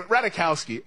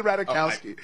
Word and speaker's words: Ratajkowski: Don't act like you don't know how to say her name. Ratajkowski. Emily Ratajkowski.